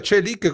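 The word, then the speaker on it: Çelik: Ecco, si pronuncia Celic come si scrive.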